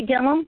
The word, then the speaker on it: Gilliam: I am Amanda Gilliam, which is spelled like William, but with a G instead of a W, on Facebook.